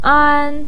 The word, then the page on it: an: 1